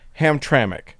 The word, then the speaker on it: Hamtramck: But Jim has written in to say, in case you need to cover events there again, the city of Hamtramck is pronounced as if there is a vowel in the last syllable, as in Hamtramck, with emphasis on the middle syllable.